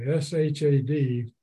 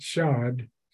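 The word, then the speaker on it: shad (שד): So Shad or Shad, S H O S H A D, is how it's spelled in English, but the A is with an ah sound, so Shad.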